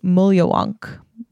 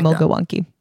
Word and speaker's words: Mulyawonk: So the Aboriginal version of bunyip is pronounced Mulyawonk when I was saying like Mulgawunky.